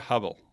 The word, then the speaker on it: Hubble: Hubble, ¿cómo, se pronuncia? – Bueno, pues quizá la pronunciación en inglés más correcta sería Hubble. Hubble. Bueno, yo digo Hubble, ¿vale?